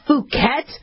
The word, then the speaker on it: Phuket: It's spelled P-H-U-K-E-T. I'm going to just pronounce it Phuket, but it's pronounced the other way. Like, just Phuket. Okay, but I didn't say it. I didn't say short U. I said long U.